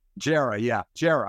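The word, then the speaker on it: Djere: Give me that pronunciation again. – Jera. – I always want to use the D.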